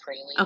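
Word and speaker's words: Pralle: Is it Praley? – Yeah, it's pronounced Praley.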